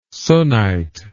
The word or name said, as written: Sunnite